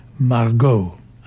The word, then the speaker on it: Margaux: And the tune that was written and arranged by Buck Clayton is called Margot, but it's not M-A-R-G-O-T. It's spelled M-A-R-G-A-U-X, Margot, as in French, Margot.